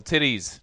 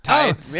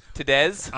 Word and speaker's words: Tiddes: This is Marlon Wayans joint, directed by Michael Titties. – What? That's the perfect pronunciation. Michael Titties. – Yep, I'm just going to say it and own it. Tides. – Oh, yeah. T-I-D-D-E-S. – Tides.